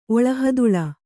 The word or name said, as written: ಒಳಹದುಳ